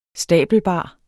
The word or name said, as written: stabelbar